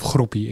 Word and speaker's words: Groupie: Ehm, groupie. Een schitterende naam natuurlijk. Of groepie. Ik weet niet precies hoe ze het zelf uitspreken. – Nou, als, van, ze, als, ze, zeggen tikkie, zeggen ook groepie.